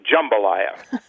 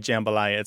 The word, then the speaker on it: jambalaya: when people do say jambalaya, it's a super short A. It's jambalaya like that.